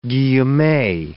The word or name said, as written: guillemet